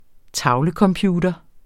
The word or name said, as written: tavlecomputer